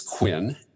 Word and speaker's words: Kwin: My name is Kwin, and it's spelled K W I N.